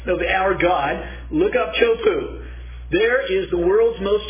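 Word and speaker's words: Teahupo'o: It's called Chopu. That's the way you pronounce it. Looks like Tehupu, D-E-A-H-U-P-O. Look it up.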